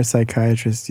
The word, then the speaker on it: psychiatrist: Why can't you hear a psychiatrist using the bathroom? This one's going to make both of you laugh so hard. Because the P is silent.